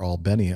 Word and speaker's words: Albany: Which is, of course, the local affiliate in Albany, Georgia, or Albany, a lot of people pronounce it.